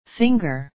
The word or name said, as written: finger